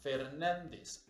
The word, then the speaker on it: Fernández: Yo me llamo Álvaro Gordoa Fernández. Álvaro es una palabra esdrújula, se tendría que tildar, tener acento en la A. Y pasa lo mismo con el Fernández, debe de llevar un acento pero en ningún documento aparezco con los acentos.